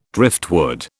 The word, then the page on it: driftwood: British English